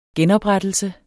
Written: genoprettelse

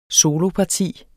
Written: soloparti

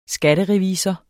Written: skatterevisor

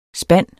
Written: spand

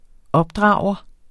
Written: opdrager

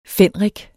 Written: fenrik

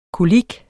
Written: kolik

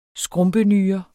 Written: skrumpenyre

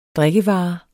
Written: drikkevare

